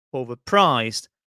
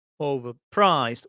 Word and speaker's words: overpriced: Notice where the stress is, overpriced, overpriced on the third syllable.